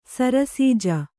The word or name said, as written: ಸರಸಿಜ